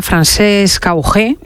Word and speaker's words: Francesc Augé: Tenemos con nosotros a Francesc Augé, espero decir bien el nombre, que es un caballero de 56 años, que ha tenido 4 ictus y 2 infartos, ¿es así? ¿Lo digo bien? – Así perfectamente.